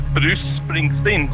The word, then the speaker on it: Bruce Springsteen: Det uttalas Bruce Springsteen så att nästan så att C:n och S:n är nästan samma.